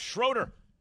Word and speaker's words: Schroeder: When he's if he's in Germany, they can call him Schroeder. In America, that's pronounced Schroeder. All right.